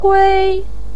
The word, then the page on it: kui: 1